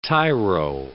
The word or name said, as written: tyro